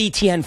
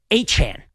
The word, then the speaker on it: Etienne: Although he doesn't say, I apologize to Travis Etienne because he doesn't pronounce, we've said Etienne for years. He actually pronounces it Achan.